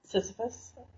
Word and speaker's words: Sisyphus: Sisyphus.